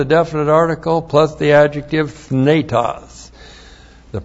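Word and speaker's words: Thnetos (θνητός): T-H-N long E-T-O-S. Thnetos.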